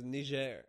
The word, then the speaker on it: Niger: The country's name is Niger. And their slogan is, make sure you pronounce the je. Niger. – Wait, it's actually Niger, not Niger? – It's French.